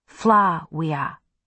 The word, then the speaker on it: Flavia: "You know, the V is supposed to be pronounced W." – "What?" – "In Flavia, It's supposed to be pronounced Fla-wea.